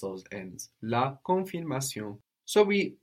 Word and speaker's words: la confirmation: And we have less N, no? On both of those N's, a lot less N. – Okay. La confirmation. – Very good. La confirmation. And also, not pronouncing that N kind of keeps the vowel in a different place in the mouth, which is much more French.